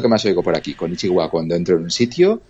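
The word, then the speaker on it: konnichiwa (こんにちは): Sí, konnichiwa es hola. Es un poco largo, ¿no?, para decir hola, pero sí, es konnichiwa.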